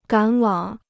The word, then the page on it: 赶往: gǎn wǎng